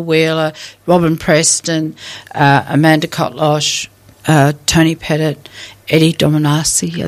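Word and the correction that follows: Dogramaci: I think I said that right. – Dogramachi. Yep.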